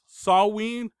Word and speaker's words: Samhain: It's pronounced Saween, but to us, it's spelled Samhain.